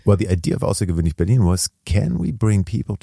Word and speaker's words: AusserGewöhnlich Berlin: And so in your network in AusserGewöhnlich Berlin.